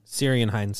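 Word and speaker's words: Ciarán Hinds: Kieran? – Syrian Hines. – Syrian? – Yep.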